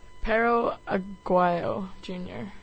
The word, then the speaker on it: Pero Aguayo Jr.: Pero Aguayo Jr. And is currently facing manslaughter charges.